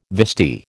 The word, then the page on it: vestee: American English